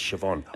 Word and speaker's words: Siobhan: How many people, including myself, you read it? Siobhan? – Cioban. – Hi, Cioban. It's Siobhan.